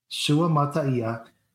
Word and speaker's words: Suamataia: But I have Suamataia. – Six syllables. Suamataia. – Suamataia.